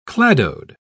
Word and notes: cladode: British English

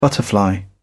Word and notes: butterfly: British English

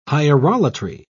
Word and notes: hierolatry: British English

